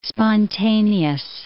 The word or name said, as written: spontaneous